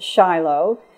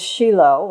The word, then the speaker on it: Shiloh: In English, we pronounce it Shiloh. In Hebrew, it is Shiloh or Shiloh.